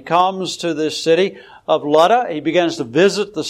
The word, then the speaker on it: Lydda: It's called Lud in the Old Testament, either L-O-D or L-U-D, depending on your Bible translation. And it's pronounced Lydda, even though there's a Y in it.